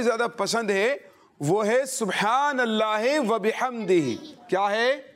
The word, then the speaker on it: سبحان اللہ وبحمدہ: ہاں, اس کے تلفظ بھی درست کرنا ہے. صبح با میں کل کلا آپ نے ضرور کرنا ہے, اور ہا کا تلفظ بھی آپ نے پیش نظر رکھنا ہے, سبحان اللہ وبحمدہ. جی ہاں, کوشش کریں اپنی زبان سے اللہ پاک کا ذکر کرتے رہیں اور اس کی برکتیں حاصل کرتے رہیں.